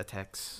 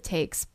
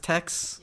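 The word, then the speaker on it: Taix: I I said a tex. – Yeah, I called it takes, but you know. – Is it just tex? – Yeah. Okay, tex. So.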